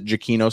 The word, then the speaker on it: Giacchino: The man himself pronounces it Giacchino, so that's what we're going to go with.